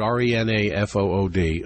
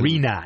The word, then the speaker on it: Renafood: No, Rent-a-food, R-E-N-A-F-O-O-D. Again, Rena, as in Rena. – Rena. Yes. Some people pronounce it Rena-food.